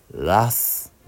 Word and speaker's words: wrath: 答えはラスです。ラス、ラス。 WRATH、ラス。発音難しいですね。